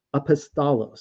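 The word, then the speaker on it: apostolos (ἀπόστολος): In Greek, it is, you can see the Greek letters right there, it is pronounced apostolos, so it's pretty close, apostle.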